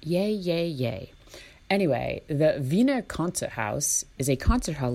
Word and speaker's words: Wiener: but it is the Wiener Konzerthaus, which I think that's literally just concert house in Austrian German. And Wiener, W-I-E-N-E-R, I'm pronouncing as a V because I think that's what they do over there.